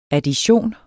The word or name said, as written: addition